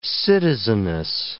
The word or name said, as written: citizeness